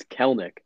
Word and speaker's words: Kelenic: His name is spelled K-E-L-E-N-I-C. You'd think, oh, it's Kelenic. No, it's Kelnick. Yeah, sucks.